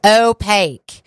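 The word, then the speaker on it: Oh.pake: It's pronounced like opaque, as in not transparent, the opposite of transparent being opaque. But they spell it a really cool way. So they spell it O-H period P-A-K-E, like opaque.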